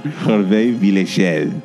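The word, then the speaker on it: Hervé Villechaize: How do you say this name? – Hervé Villechel.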